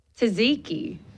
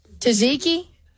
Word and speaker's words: tzatziki: Tzatziki.